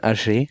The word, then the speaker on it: Hergé: Übrigens, er wird nicht Herge ausgesprochen, sondern Hergé.